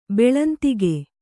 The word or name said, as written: ಬೆಳಂತಿಗೆ